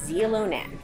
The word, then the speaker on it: Xilonen: It's that you spell it X-I-L-O-N-E-N. That's the name of the restaurant, pronounced Zilonen.